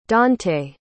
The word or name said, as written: dontaye